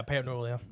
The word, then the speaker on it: pareidolia: So your mind kind of, it's like that, what's that called, pareidolia? – Yeah, pareidolia. – Is that, am I saying that right? Yeah. – Yeah, no, that's the correct word, finding human faces.